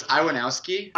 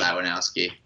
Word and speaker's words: Iwanowski: How do you pronounce your name? – Okay, so it's Iwanowski. – Iwanowski, okay. – Which is pretty Americanized. – I'd always go the German way with and make the W a V, say Iwanowski. – Right, yeah. So that's not – I don't correct anybody. Pronounce it how I want. But how my family's always said it is Iwanowski.